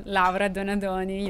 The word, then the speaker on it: Laura Donadoni: I see that my name is quite of a challenge to pronounce, but it's good. Like Pinot Grigio that we are talking about and Laura Donadoni, you know, Italian names can be challenging, but, you know, the wine make it better, right? – Make it a lot better.